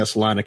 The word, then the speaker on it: Salina: But now she decides, like, today I'm going to take, Kobe around, Salina. Am I pronouncing that right? Was it Salina? – Yeah, Salina, Kansas. Yeah. – Nailed it.